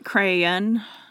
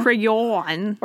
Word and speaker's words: crayon: It's more like crayon. – Yeah, it's like crayon. – Yeah. – Yeah. But I think the best one I can say is one syllable. It's sure. closer to one than it is to two. – Yeah. – Crayon, like dawn. – Crayon. – Or crayon. – Crayon. – Or crown. I don't know who says that.